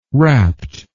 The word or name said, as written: wrapt